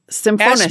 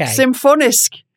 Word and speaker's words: Symfonisk: Symphorisk? How do you pronounce this stuff? – Symphonisk. – S-Y-M-F-O-N-I-S-K. – Symphonisk.